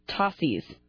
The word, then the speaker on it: Tosis: It's pronounced Tossies. But it's spelled Tosis, T O S I S.